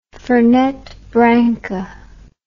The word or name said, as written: Fernet Branca